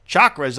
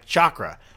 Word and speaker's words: chakra: You think I'm like, chakras? Don't you mean chakras? No, apparently you say it like chakra, like chocolate, or Chaka Khan.